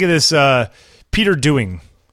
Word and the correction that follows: Doig: Doig? Is Doig? There an N? There's no Ed.